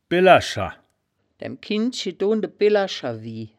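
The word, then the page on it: billerscher: wittlicher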